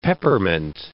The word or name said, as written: peppermint